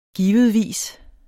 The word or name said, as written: givetvis